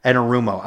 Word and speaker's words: Anarumo: Like, we've talked about Lou Amarillo. Am I saying his name right? – Anarumo. – I was going to say Lou Amarillo, and I'm like, that's not his name.